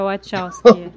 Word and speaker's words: Wachowski: Eu falo Wachowski, não sei se é o correto. É, Wachowski.